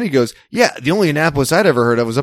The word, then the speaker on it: Annapolis: I was like, yeah, it's pronounced Annapolis.